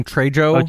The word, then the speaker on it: Trejo: Trejo. – Danny Trejo. – Trejo, who was in The Devil's Rejects. – Yeah, that's right. He was. – And he's in this. – And Danny also. – Trejo,